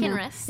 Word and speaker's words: Kinrys: Kinrys.